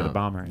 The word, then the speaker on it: Bombher: Yeah, the bomber. Yeah, yeah. But it's spelled B-O-M-B-H-E-R. They threw an H in there.